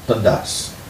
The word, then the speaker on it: Dundas: Oh, this is going to be terrible to pronounce for those of you who speak English. It's called Port Dundas. – Dundas? – Dundas.